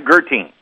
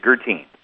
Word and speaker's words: Guertin: In different parts of the country, it's actually pronounced differently. Here in good old Bourbonnais, Illinois, it's Guertin.